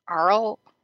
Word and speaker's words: Arles: And in 1863, the vines began dying for no apparent reason in a place near A-R-L-E-S. Arles? – No, it's Arles. You would pronounce the Arles. S on the end.